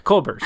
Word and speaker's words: Kolbert: Did I say it wrong already? I just looked it up. Kolbert. There we go. Kolbert. Yeah, like Kolbert. Kolbert.